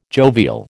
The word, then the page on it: jovial: American English